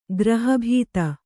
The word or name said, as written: ಗೃಹಭೇದಿ